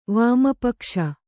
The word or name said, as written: ವಾಮಪಕ್ಷ